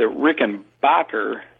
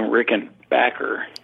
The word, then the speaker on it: Rickenbacker: Nazi Germany, the war broke out and everything, the Rickenbacker name was German.